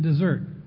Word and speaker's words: desert: The sermon title is Just Dessert. One S, but it's pronounced like the two S.